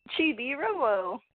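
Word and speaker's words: Chibi-Robo: We have Guitar Hero, Chibi Robo. Did I say that correctly? – Chibi Robo.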